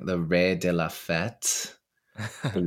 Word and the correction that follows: raison de la fête: raison, the raison de la fête.